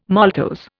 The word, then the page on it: maltose: Indian